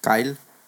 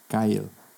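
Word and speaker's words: X Æ A-12: ¿Cómo se pronuncia el nombre del hijo de Elon Musk, güey? – Kyle. – Kyle. Kyle. ¿Y cómo se escribe? Se escribe C-H-I eh, a e como el de American Express, y luego eh, X12.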